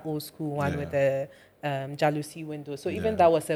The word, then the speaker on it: jalousie: Thanks for the proper pronunciation, by the way. Everyone has been saying jealousy. – Mm. Jalousie. – But it's like a French jalousie.